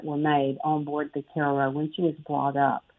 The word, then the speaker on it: Cairo: So she spelled the same way as Cairo, Egypt, but pronounced Cairo.